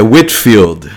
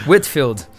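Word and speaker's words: Whitefield: legendador e está fazendo seu mestrado pela Waterfield Theological Seminary em Lakeland, Califórnia. Espero ter pronunciado corretamente o Waterfield. acho que é o É, é Whitfield. – Whitfield. Isso. Ok.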